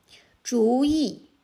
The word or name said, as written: 主意